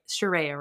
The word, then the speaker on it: Shireya: Shireya, right? – Yeah, you actually got it perfectly. Usually people will be like Shireya, but it's pretty phonetic, like how it's spelt.